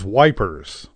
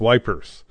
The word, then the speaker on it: Ypres: And they invariably, as people who speak English do, referred to it as wipers. Y-P-R-E-S, wipers.